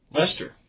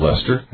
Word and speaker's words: Leicester: This is from Massachusetts, but before I say the name, let me spell it for us. L-E-I-C-E-S-T-E-R. – Lester. – I'd call it Lester.